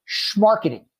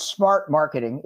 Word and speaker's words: schmarketing: It's the pronunciation of schmarketing. Say it like you're from Brooklyn. – Schmarketing. Schmarketing. – Sales and marketing pushed together. Some people think it's smart marketing. It's not. It's sales and marketing pushed together, which I made up in 2007 when I was drunk, right?